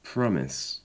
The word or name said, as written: promise